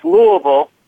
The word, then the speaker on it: Louisville: It's Louisville.